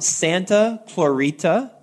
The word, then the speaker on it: Santa Clarita: Will is joining us from Santa Clarita, California. Did I pronounce that right? – You got it.